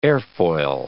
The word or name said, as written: airfoil